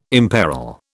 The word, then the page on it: imperil: British English